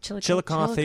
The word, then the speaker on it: Chillicothe: Chillicothe at 10:30 p.m.